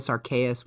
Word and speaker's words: Arceus: that the reason it's pronounced Arceus, whichever, with a hard C or K sound in English-speaking territories is because Arceus sounds too much like arse.